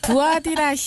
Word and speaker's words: 두아디라: 도아디라 시에 있는 자색옷감 장사로서. – 목사님 잠깐만요. 네. 두아디라 시요. 두아디라 시.